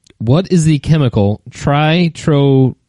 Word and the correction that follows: Trinitrotoluene: I totally butchered that. Trinit. I cannot pronounce this. – Okay. – Trinitrotoluene.